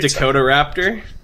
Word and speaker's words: Dakotaraptor: After narrowly escaping another plasma Dakotaraptor and dispatching it with a magnetic field generator... – You mean Dakotaraptor? – Okay. – It's Dakota Raptor, like South Dakota.